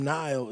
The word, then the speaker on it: Nile: In high school, I dated a Nikkei, which is spelled N I K E, and my name Nile is spelled N I L E.